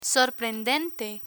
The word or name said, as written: sorprendente